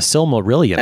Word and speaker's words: Silmarillion: Tolkien called The Similarian. Have you read this book or no? The the Silmarillion. – There you go. I never pronounce anything right.